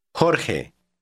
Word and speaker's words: Jorge: The letter J is pronounced the same way. Read word 9. – Lejos. Lejos. – 10. – Juan. – Try word 11. It's the name George. – Jorge.